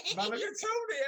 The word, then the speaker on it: Mulligatawny: Mulligatawny, absolutely right.